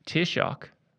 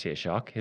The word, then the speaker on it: Tairseach: Tairseach, pronounced Tairseach, is the Gaelic word for threshold.